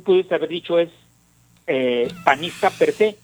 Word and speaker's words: per se: Fíjate, acento en la E, guinda per se.